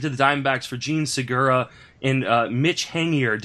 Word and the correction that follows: Haniger: Did I say that right, Mike? – I'm not sure. I know nothing about it. Matt's on the podcast. So we're not going to be correcting my pronunciation. – It's actually Haniger.